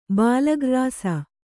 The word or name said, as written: ಬಾಲಗ್ರ್ರಾಸ